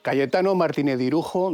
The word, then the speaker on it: Cayetano Martínez de Irujo: Cayetano Martínez de Irujo, duque de Arjona y conde de Salvatierra. ¿Lo he dicho bien? – Sí.